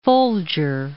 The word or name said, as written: Folger